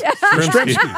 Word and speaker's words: Yastrzemski: Did I say it right? – You did? – Yes. – Yastrzemski. Yastrzemski. Yes. Yastrzemski. – I saw everyone laugh when I said it, so I had to double check.